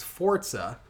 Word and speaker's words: Forza: Forza which I learned it's pronounced Forza. I think we all learned that day.